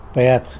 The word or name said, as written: Bed